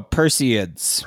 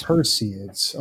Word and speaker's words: Perseids: Is that Perseid or? – Oh, Perseids. – Perseids.